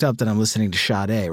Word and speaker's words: Sade: I think it's like Sade, but you do it with like you're like not paying attention to the fact that you're pronouncing a sh at the beginning. It's like Sade. It's a quick sh.